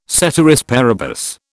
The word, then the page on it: ceteris paribus: British English